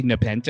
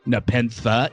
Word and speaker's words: Nepenthe: You know, did you guys know, Sean, did you notice that some people say Nepenthe and some people say Nepenthe? Like, some people pronounce the H on this episode and some people don't.